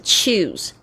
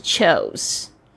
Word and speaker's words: choose: choose 这个字也要注意，也有很多同学不知道怎么样子去发音，是 choose 不是 chose。 choose C-H-O-O-S-E 这个 O-O 发 U 的这个长音呢 choose choose。